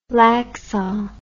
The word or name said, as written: laksa